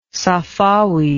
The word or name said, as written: safavid